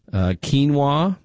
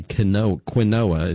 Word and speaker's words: quinoa: So whole grains are things like whole wheat, again, oats, brown rice, quinoa, that's with the Q, if you pronounce it differently, quinoa, as some people say, barley and rye.